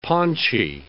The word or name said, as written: paunchy